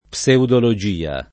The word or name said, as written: pseudologia